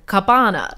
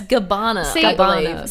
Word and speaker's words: Gabbana: It all had to do with how we pronounce, now I've got to be careful, Dolce and Gabbana. – No, you did it again. Oh, it's Gabbana.